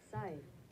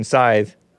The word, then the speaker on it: scythe: S-C-Y. Let's hear it. Let's hear it. Scythe. Oh, no. That's American pronunciation, scythe. Scythe. Oh, British pronunciation is also scythe. Wait. Sounds like scythe. I don't like that. I don't know. I don't like I don't like them pronouncing it properly. But it makes sense to that it would be pronounced scythe, actually.